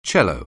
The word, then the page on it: cello: anglais